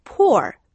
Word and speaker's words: poor: P-O-O-L, pool. 네, 가난한, 불쌍한이란 뜻의 poor, P-O-O-R과 발음이 조금 비슷한데요. – 네. – 수영장은 pool이고요. 불쌍한, poor, poor로 발음합니다.